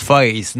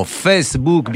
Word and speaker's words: Facebook: Il a bien raison de ne pas le prononcer à l'américaine. Trop de gens disent Face. Non, Facebook, bien de chez nous.